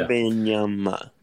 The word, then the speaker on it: Wembanyama: So realistically, do you guys think that the Pacers are in the Victor Wembanyama sweepstakes? Did I say it right? – Yeah, yeah, you said okay. it. Yeah, Wembanyama.